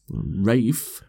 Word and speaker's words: Ralph: Is it Rafe? I'm full of cold. Fuck off. Leave me alone. – You've got to pronounce it right. Rafe. The only reason I know it's pronounced Rafe is because Amy adores him.